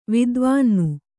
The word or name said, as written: ವಿದ್ವಾನ್ನು